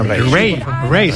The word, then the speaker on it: Raye: Ray. Se escribe Raye. Raye con ella.